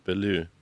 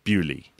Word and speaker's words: Beaulieu: B-E-A-U-L-I-E-U. – Baloo. – You actually pronounce that. You're meant to pronounce it. Bewley. – Bewley. – Bewley. Well done. But people say Bailu. Oh, I live in Bailu. But it's actually Bewley.